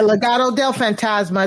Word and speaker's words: Legado del Fantasma: Legado, did I say that right? – Okay, yeah, Legado del Fantasma.